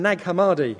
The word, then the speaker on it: Nag Hammadi: However, the Gnostic, the G is silent, the Gnostic Gospels from Nag Hammadi, where the G is not silent, make Jesus less, not more human.